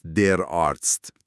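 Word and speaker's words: Arzt: فرم مذکرش هست در آرتست. تلفظش هست در آرتس. اون TS آخرش مهمه، مثل تس.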